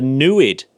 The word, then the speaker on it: NEID: Yes, and she pointed out the nuid, which rhymes with fluid. The nuid. – Nuid. – N-E-I-D.